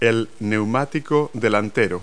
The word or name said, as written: front tire